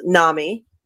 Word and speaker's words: NAMI: And I don't know how many people know this, but Mick donated a significant amount toward Daphne's causes, which was NAMI, National Alliance Mental Institute. Am I saying that right? I just know him as NAMI. Yes, thank you.